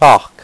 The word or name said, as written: parc